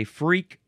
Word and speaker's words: Freq: Moving on to Freck. I don't think that this is not a word that we say because it's an abbreviation. You could also say freak. It is spelled F-R-E-Q.